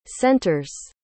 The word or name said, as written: center's